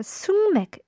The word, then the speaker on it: 숙맥: 숙맥이라고 하는데 이 단어는 숙맥이라고 흔히들 읽지만 사실 숙맥이라고 발음을 해야 한다고 합니다. 숙맥. So I think we use this word when we're not used to something like uh, you're a sungmek in a relationship.